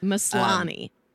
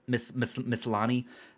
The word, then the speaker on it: Maslany: Is that how you say her name? – Maslany. – Maslany.